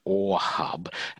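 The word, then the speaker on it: AweHub: But I'm actually more interested in the company name, which is OrHub. And when I first heard that, my brain spelt it O-R. And I thought, well, what's that then? But it's not. It's spelled A-W-E. And that's two separate words.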